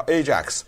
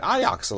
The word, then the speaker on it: Ajax: 如果你家里有那个什么洗碗的什么的，对，他有一个著名的品牌叫 Ajax，就是说我力大无比。但是在荷兰语里面呢，同样的这个字它不念 Ajax，它念 Ajax，所以这一点呢，如果你要是听到新闻报道的时候，你觉得奇怪，哎，是 Ajax 怎么变 Ajax 了？对，这是人家荷兰语的这个发音。